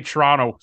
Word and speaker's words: Toronto: But I had to try and teach them how we say Toronto so you don't sound like a total tourist. We don't pronounce the second T. There's no second T in Toronto. – Barely pronounce the first one, too.